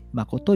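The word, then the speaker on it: 麻姑: マコとは朝に姑と書いてマコと読みます。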